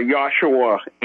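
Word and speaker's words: Yeshua (ישוע): Jesus will know you are addressing him, even though I believe it's pronounced Yeshua or Yahshua in Aramaic.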